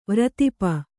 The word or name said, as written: ವ್ರತಿಪ